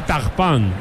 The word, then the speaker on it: Turpin: Se dice, se escribe Turpin, se dice Tagpan en francés.